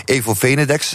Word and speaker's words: Evo Venedex: Mijn gasten vandaag, Rob Zomer, lid van het managementteam van ondernemersvereniging Evo Venedex, zeg ik het goed? – Uitstekend.